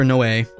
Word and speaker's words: Noé: Like, I'm a big fan of Gaspar Noé, and it's not pronounced no, folks.